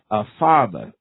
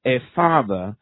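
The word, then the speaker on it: Ephphatha: And then looking up into heaven, he sighed and said to him, Ephatha, Ephatha, it's pronounced it wrong, I've been working on that, Ephatha is how that is pronounced, Ephatha, which translated means be opened.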